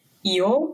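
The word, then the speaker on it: Egå: Если быть точной, город, пригород, которым я был, мой проект называется Ио по-датски, но пишется он Эго, поэтому все его называют Эго.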